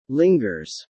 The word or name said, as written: lingers